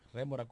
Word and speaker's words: rémora: Debemos agradecer a la ex senadora Marily Santana por su aporte para salir en demora a Colorada, en esa remora a, a Colorada. – Colorada. – De esa remora. Rémora, Colorado. – Rémora, ¿eh? – Sí. – Ah, le faltó eh, el acento en la E.